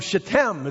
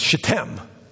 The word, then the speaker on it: Shittim: He sent two men as spies secretly from Shittim, it's pronounced. And I know pastors always struggle with that word. They don't want to offend anybody. I'm not worried about that. But I did get the Hebrew. It's Shittim.